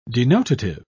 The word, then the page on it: denotative: British English